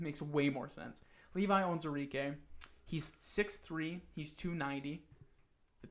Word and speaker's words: Onwuzurike: Why am I not saying this right? – Onwuzurike. – Onwuzurike. – That's how I've heard it pronounced. – Onwuzurike. That's what it is.